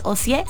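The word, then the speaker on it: Ocyé: mi nombre no lleva L al final, es Ocie, sin L, C-Y-E, acento en la E.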